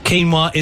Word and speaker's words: quinoa: Quinoa is correct, but most people pronounce it...